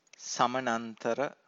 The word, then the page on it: samanantara: Pali